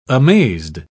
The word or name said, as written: amazed